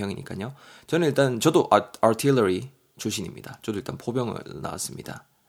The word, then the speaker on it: artillery: artillery 여러분 발음이 약간 어렵죠. artillery, artillery 정도 되고요.